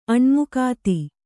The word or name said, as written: ಅಣ್ಮುಕಾತಿ